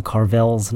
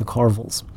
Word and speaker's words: Carvels: Forgot the NYC because it's very important that we put the correct band name, sort of like how I mispronounced their band name and they corrected me and said it was the Carvels, not the Carvels.